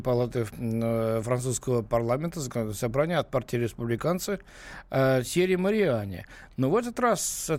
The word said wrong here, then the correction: Тьерри Мариани: Простите, вас поправлю немножечко. Тьерри Мариани, потому что это французы. У французов идет ударение на последний слог.